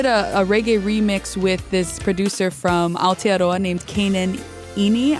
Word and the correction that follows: Ene: or Ene is how you pronounce his last name.